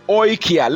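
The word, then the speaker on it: Oikia (οἰκία): Now the word house is translated in the Greek as oikia. Let me spell it for you. O-I-K-I-A.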